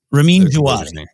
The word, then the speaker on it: Ramin Djawadi: Ramin Djawadi.